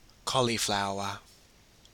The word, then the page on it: cauliflower: Australian English